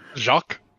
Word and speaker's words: Jacques: Jacques? – Jacques. I don't know why I'm so bad at remembering. – It's just French, Jack. – Jacques. – I literally wrote J-A-C-K.